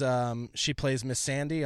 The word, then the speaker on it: Missandei: She plays Miss Sandy on Game of Thrones. She's like There was a Miss Sandy? – It's spelled like M-I-S-S-A-N-D-E-I.